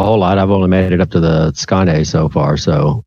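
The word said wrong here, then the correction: Skåne: It's Skana, in case you guys have forgotten.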